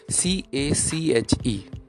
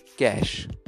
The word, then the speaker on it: cache: Our first word is C A C H E. It is commonly mispronounced as cache but its right pronunciation is cash. I will repeat it cash.